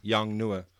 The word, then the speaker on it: Yongnuo: That's how you pronounce it, John. Yongnuo.